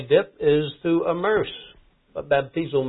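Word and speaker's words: baptizo (βαπτίζω): All right, baptizo. So this is a transliteration of the Greek and the phonetic pronunciation DZ, but anyway, baptizo.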